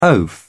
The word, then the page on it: oaf: British English